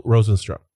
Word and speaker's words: Rozenstruik: How you say his name? – Jarenzo Rozenstruik.